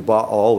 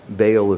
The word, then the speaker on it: Baal: He sets up a temple to Baal, or Baal if you prefer. Often we pronounce it that way.